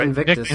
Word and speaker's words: Invictus: Invictus? – No, you're right. Invictus.